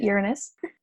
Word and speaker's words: Uranus: So recently, the planet Uranus, which you can pronounce different ways, but I, I pronounce it Uranus, that planet just moved into the sign of Taurus.